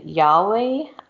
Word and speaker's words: Yahweh: Um, so in Judaism, Satan is seen to is seen as an agent subservient to Yahweh.